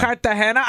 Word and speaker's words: Cartagena: Cartagena. I knew that's what how you say it.